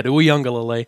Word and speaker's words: Uiagalelei: Prior to that, though, I think you look at the young quarterback, DJ Uiagalelei, and I've just learned how to pronounce that, so I'm going to say it every opportunity I get, Uiagalelei.